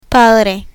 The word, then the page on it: padre: Spanish